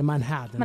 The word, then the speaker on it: Manhattan: E loro non pronunciano la T, se non lo sapete. – Sì, è complicatissimo dire Manhattan.